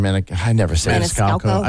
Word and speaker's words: Maniscalco: And this is where, I mean, you know, Sebastian Maniscalco.